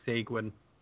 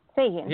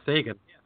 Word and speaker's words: Seguin: It's still not as bad as Joe saying Seguin. – Oh. – Seguin. – Still wrong. – Well, that's how I say it. – Tyler Seguin, right? Sagan. – Yeah, Sagan. – Yeah, Sagan. I'm saying it right. – Okay, both of you. – Joe is saying it like it's Penguin.